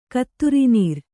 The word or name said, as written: ಕತ್ತುರಿನೀರ್